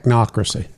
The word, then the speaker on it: Technocracy: Techocracy. Is that how you pronounce it? – Technocracy. – Technocracy.